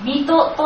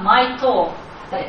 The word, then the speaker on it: ミト: ミトという地名すら聞いたことがないのです。事実、ここに到着するまで間違ってミトの発音はマイトー、英語で読むとマイトーって読めると私のつま先って意味でと思ってトー発音していたんです。